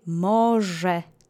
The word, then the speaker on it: może: The accent is on the first syllable. Let's say może together slowly three times.